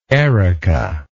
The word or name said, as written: Erika